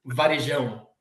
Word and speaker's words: Varejão: I think it's a little bit difficult to say, like, Anderson Varejão is the correct pronunciation in our language.